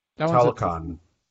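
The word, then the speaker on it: Talocan: What's it called again? Tanakana or something like that? That Talakon. – Yeah, it's a tough one to pronounce.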